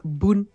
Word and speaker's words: Bun: no sé si escucharon sobre el nuevo framework se llama Boon, se escribe B-U-N.js.